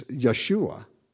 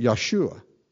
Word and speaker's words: Yeshua: It's Yeshua. in the Hebrew, that's the way they pronounce it. They don't pronounce it Joshua like we do. They pronounce it Yeshua, Jesus, same name.